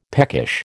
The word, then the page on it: peckish: American English